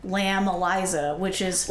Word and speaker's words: ELISA: They pronounce it Eliza, but it's spelled Lamb Elisa.